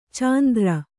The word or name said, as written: ಚಾಂದ್ರ